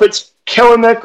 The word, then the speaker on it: Kelenic: I'm sure I keep butchering that last name, Kelenic. I don't know if it's Kelenic or Kelenic. I believe it's Kelenic. – Mm-hmm. – Kelenic. Okay. It's that extra E there that always throws me.